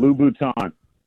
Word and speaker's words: Louboutin: You got to pronounce it with the French Louboutin. – Sorry. – You can't go Louboutins. That's my bad. – Louboutin.